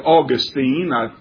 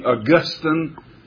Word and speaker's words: Augustine: Another man who was just as famous for believing that as Calvin was, it was St. Augustine, or I usually pronounce it Augustine. I've heard it pronounced more recently Augustine.